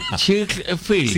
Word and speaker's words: Chick-fil-A: Chick-fil-A. – Sí, así se escribe. – En Boston, Estados Unidos. – Sí, como de chicken, como de pollo, sí.